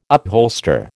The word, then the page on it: upholster: American English